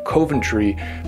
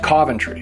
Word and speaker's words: Coventry: Coventry is a town in the Northeast Kingdom that looks like maybe it's supposed to be pronounced Coventry, but it's Coventry.